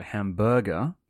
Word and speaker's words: hamburger: Thank you for leaning on the first syllable there, by the way. Hamburger.